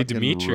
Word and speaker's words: Dimitri: It's because you're not saying it with your natural accent. Dimitri. – That's like Voldemort or some vampire. – Yeah. – So I would say Dimitri. – Russian fucking Transylvania.